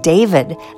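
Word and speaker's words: D4VD: Go from June gloom smiling to a set I'm lovingly calling my sad sack segment, starting with emerging artist David, that's spelled D4DV, from Houston by way of Queens, New York.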